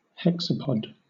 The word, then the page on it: hexapod: British English